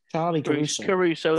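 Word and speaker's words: Charly Caruso: Is it Charlie Caruso or Caruso? I never know how to pronounce it. – Charlie Caruso, that's it.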